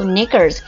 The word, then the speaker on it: knickers: 好，还有就是这个 knickers， knickers 是指女生的这个底裤啊，女生的底裤 knickers， K 是不发音的底裤。